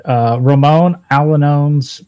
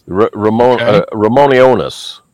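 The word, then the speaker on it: Ramón Allones: Number 5 is a Cuban cigar, but Ramon Alanones by AJ Fernandez. – Ramonionis is how it's pronounced. – Ramonionis. – Yes, that's correct.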